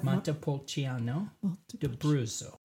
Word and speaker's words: Montepulciano d'Abruzzo: Montepulciano d'Abruzzo.